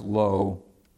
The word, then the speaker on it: Louw: And I think it's pronounced low. Actually, I think it's spelled O-U. They're L-O-U-W.